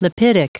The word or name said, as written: lipidic